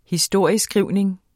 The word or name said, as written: historieskrivning